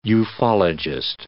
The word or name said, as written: ufologist